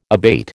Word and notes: abate: American English

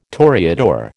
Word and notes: toreador: American English

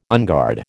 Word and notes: unguard: American English

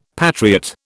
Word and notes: patriot: British English

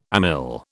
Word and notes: amyl: British English

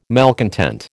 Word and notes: malcontent: American English

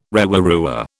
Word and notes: rewarewa: British English

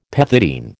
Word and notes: pethidine: American English

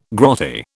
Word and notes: grotty: British English

